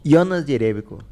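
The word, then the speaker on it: Jerebko: Jonas Jerebico, que na verdade parece que o nome, a pronúncia adequada seria com os dois J's mudos. Jonas Jerebico.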